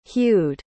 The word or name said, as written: hewed